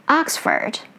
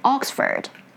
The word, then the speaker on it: Oxford: I got the nickname Miss America because I pronounced the ah sound instead of o for a specific vowel sound. Oxford, American way, and Oxford, British way.